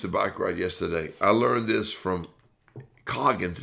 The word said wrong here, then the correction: Goggins: Is that the way you say his name? – Start with a G. Goggins.